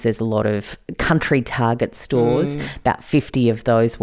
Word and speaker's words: Target: Sorry, Larissa, I think the correct pronunciation is Target, but we'll move past that.